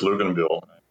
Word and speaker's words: Luginbuhl: My name is Chris Luginbuhl.